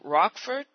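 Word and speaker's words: Rochford: On the subject of Sons of Kemet, you have two drummers, Tom Skinner and Sebastian Rockford. Am I pronouncing that? – Yeah.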